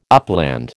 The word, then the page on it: upland: American English